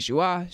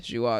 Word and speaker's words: Joie: You guys can check out my movie reviews or TV reviews on Letterboxd or on Serialized at Demi Joie. Joie is spelled J-O-I-E.